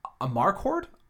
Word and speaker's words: Amarcord: And I feel like I always say this wrong. Amarcord? – No, I'd say that's right.